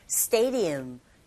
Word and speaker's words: stadium: D 사운드 자체가 르에 가깝게 들리게 되죠. Stadium.